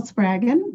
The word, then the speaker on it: Spraggon: So my name is Adele Spraggon, rhymes with dragon, so easy to remember.